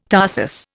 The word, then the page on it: tarsus: Indian